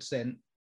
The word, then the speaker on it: Sen: Zen Mango, Zen rhymes with Sen, Sen, S-E-N, your last name, like I got it.